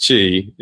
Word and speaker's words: Qi: Ben calls it Qi, but it's spelled Qi. It's pronounced Qi.